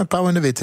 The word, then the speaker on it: Pauw en de Wit: En zeg ik dat goed trouwens? Ja, Pauw en de Wit, hè?